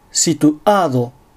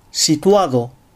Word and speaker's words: Situado: アクセントに気を取られるあまり、アディオス、エスタシオン、シトアード、マジェストオソのように、単語の途中に正門閉鎖を入れてしまう。こういう発音をするとスペイン語としては大変聞き苦しい感じになります。ここは正しく Adios Estación Situado Majestuoso と発音したいところです。